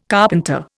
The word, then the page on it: carpenter: Indian